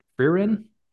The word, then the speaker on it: Frieren: It's F-R-E-I, or wait, no, F-R-I-E. R-E-N? Frieren? Frieren? – Frieren. Frieren. Okay.